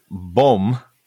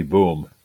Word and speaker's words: Böhm: And I can spell his surname. B-O-Umlaut-H-M. So your German pronunciation will give you much better insight into how that's pronounced. – I think it might be Böhm.